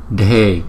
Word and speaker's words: dhe: Sada, sada, sada, sarva, sarva, sarva, gati, gati, gati, parishuddhescha, parishuddhescha, parishuddhescha, dhe, dhe, dhe. Chữ đờ và chữ hát chúng ta phải đọc nhấn mạnh một chút.